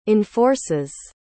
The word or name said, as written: enforces